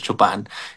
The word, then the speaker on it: Chopin: Chopin se escribe Chopin, pero es Chopin.